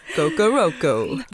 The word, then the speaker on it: Kokoroko: It's interesting that you mentioned Rosie Lowe and Kokoro, I think. Hopefully we're pronouncing that right. – Yeah, Kokoro. – I'm not sure. We'll edit in the right pronunciation. – Yeah, exactly. Kokoroko.